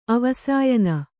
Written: ಅವಸಾಯನ

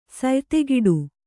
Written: ಸಯ್ತೆಗಿಡು

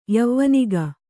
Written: ಯವಾನಿಕೆ

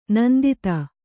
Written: ನಿಂದಿತ

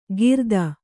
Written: ಕಿರಾತ

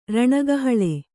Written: ರಣಕಹಳೆ

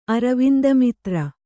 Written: ಅರವಿಂದಮಿತ್ರ

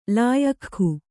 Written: ಲಾಯಖ್ಖು

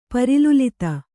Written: ಪರಿಲುಲಿತ

